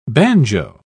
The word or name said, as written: banjo